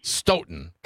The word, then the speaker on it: Stoughton: How do I pronounce your fine city properly? – Stoughton.